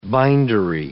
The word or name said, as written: bindery